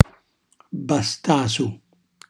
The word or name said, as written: bastasu